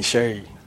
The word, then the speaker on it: Sheyi: So, Sheyi Babalola, you know, and many people find it difficult to pronounce my name, and just like you said, but I adopted the name Sheyi, as in S-H-A-Y, just to, you know, facilitate a kind of communication, you understand, you know.